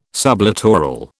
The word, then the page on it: sublittoral: British English